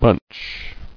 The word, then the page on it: Bunchiness: bunch